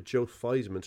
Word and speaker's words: Theismann: So he changed the pronunciation of his name to Joe Theismann to rhyme with Heisman, and then didn't win the trophy.